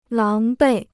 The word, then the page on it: 狼狈: láng bèi